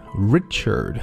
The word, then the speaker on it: Richard: Richard,